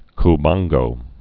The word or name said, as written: Cubango